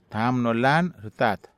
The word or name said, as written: taham nolan rtat